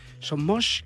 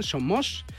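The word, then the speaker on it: Son Moix: A 10 minutos de que arranque lo de Son Mox, Sonmosh, que me, enseñó Sara el otro día a pronunciarlo bien, Sonmosh Pues vamos a ver qué ha dado de sí el partido.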